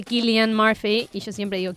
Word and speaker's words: Cillian: Es que no, pero mucha gente avisame no lo que se dice Killian y Paz tampoco sabía Ese se de risa.